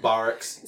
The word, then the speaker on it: barracks: The barracks? What are we? – Everybody's in the barracks. It's pronounced barracks. – Sorry, my bad.